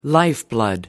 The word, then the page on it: lifeblood: American English